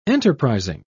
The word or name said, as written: enterprising